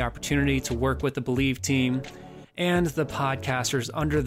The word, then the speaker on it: Bleav: The Mosaic Life Podcast has officially joined the Believe Podcast Network, spelled B-L-E-A-V, pronounced Believe, and I seriously could not be more thrilled to grow with them well into the future.